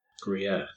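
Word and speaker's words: Gruyère: Is it a Gruyere? – Gruyere. That's how it's pronounced, nice.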